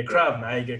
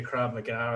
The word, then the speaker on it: Krav Maga: It was called Krav Maga, K-R-A-V. Yeah, Krav Maga, Krav Maga.